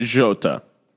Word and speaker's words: Jota: How's the J pronounced? – Jota. – Jota. Okay. So Portuguese.